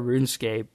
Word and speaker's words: RuneScape: And the other thing is I found out that long, long time ago that the proper way to pronounce RuneScape was not RunEscape.